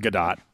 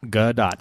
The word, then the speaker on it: Gadot: And Gadot. Yeah, it's a hard T. Dot. It's Dot. Gadot.